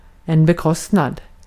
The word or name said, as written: bekostnad